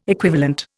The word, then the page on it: equivalent: Indian